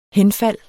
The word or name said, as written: HENFALDETS